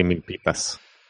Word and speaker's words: Milpitas: Now, last week you were in Milpitos. Did you survive Milpitos? – I did survive. Yeah. It's actually Milpitas, so whatever.